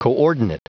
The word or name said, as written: coordinate